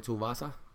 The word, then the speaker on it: Tuivasa: How do you... Ty? – Yeah. Ty Tuivasa. – Yeah. There you go. Yep. That's how you pronounce his name.